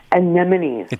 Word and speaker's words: anemone: Most people call them anemones. – Anemones. Okay. – That's the phonetic spelling, right? Anemones. But yeah. – Anemone is the correct pronunciation.